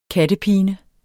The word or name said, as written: kattepine